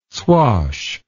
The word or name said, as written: swash